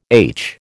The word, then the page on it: aitch: American English